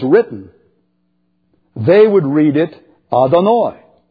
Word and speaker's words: Adonai: And when the Jews would come to YHWH, they would pronounce it Adonai. Adonai.